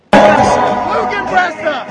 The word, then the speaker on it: Lügenpresse: Lügenpresse! You said it right.